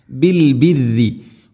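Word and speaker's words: البر: ബിൽ ബിരി, പ്രിയമുള്ള ശ്രോതാക്കളെ പ്രത്യേകം ശ്രദ്ധിക്കുക, ബിൽ ബിരി, ബിൽ ബിറി പറയരുത്, റാവിന് ശദ്ദ് കസർ, ബിൽ ബിറി അല്ല, ബിൽ ബിരി, ബിൽ ബിരി. തർക്കീഖാണ് ചെയ്യേണ്ടത് ശദ്ദുണ്ടെങ്കിലും. ധാരാളമായിട്ട് ആളുകൾ ബിൽ ബിറി എന്ന് പറയും, മിൻ ഷർറി പറയുന്ന പോലെ. ബിൽ ബിരി വതൻസൗന, നൂനിനെ അവ്യക്തമാക്കി മണിക്കണം.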